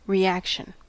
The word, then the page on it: reaction: American English